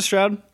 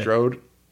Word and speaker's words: Strode: You pronounce it Stroud? I always said Strode. – Yeah. Strode. I'm an ignorant, giant dumbass, so it doesn't matter. It's probably Strode.